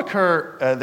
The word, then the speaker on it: Ricoeur: Ricoeur? Okay. So Paul Ricoeur, the French philosopher writing mostly in the 20th century, who died in 2005, talks about this concept called the second naivete.